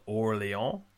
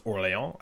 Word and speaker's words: Orléans: The next game is called Orléans. It's spelled Orleans, but it's Orléans, I think is how it's pronounced.